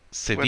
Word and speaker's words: Sevilla: I think it's Sevilla. – Sevilla. – If you're doing the Spanish pronunciation, oh yes, if you're going for the English, it's Seville. – Sevilla.